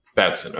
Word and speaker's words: Batson: I don't know if I'm saying that right. – Billy Batson. – Just Batson. – Batson. Okay.